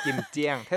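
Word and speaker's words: กิม เจียง (김장): ดิฉันอ่านว่ากิมเจียงกิมเจียงเท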